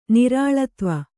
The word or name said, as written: ನಿರಾಳತ್ವ